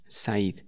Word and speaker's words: said: Y cuando quiere decir ellos dijeron, el verbo decir es say, S-A-Y, y en pasado se escribe said, S-A-I-D, said, pero se pronuncia said.